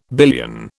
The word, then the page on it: billion: British English